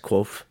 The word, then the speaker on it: Kvothe: So Quoth was asked, Quoth. – Quoth. – Okay. Think quote. And then drop the T-E. And add CH.